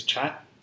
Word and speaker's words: kyat: You know, a change in money is when we were there in 2010, the local currency is chat, but which is spelled K-Y-A-T, but you say chat.